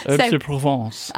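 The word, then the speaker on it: Herbes de Provence: She cooks them with some like herbs de Provence, I think is how you say it. You're French. – Oh, that was correct. – Okay. – Herbs de Provence.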